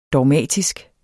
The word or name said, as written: DOGMATISKE